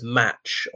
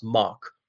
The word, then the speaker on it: Mach: and reading as match or march because it's pronounced Mark.